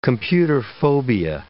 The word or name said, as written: computerphobia